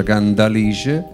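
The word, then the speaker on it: Cantalice: si chiama Candalice con la D, anche se si scrive con la T, ma si pronuncia Candalice.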